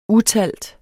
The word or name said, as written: utalt